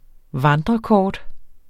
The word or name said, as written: vandrerkort